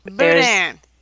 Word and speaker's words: boudin: Boudin? – Boudin.